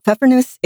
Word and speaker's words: Pfeffernuss: So Pfeffernuss is seen pretty commonly in stores and stuff, and they're really, really good.